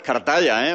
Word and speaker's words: Cartaya: Anda, Cartaya, qué bien lo pronuncia, hijo. – Verdad que sí, con todas las letras.